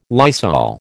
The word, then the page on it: Lysol: American English